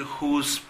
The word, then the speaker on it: whose: Ahora, cuidado que whose tiene la misma pronunciación que who is. Yo puedo decir, who's that? ¿Quién es él? Suena igual que who's de quién.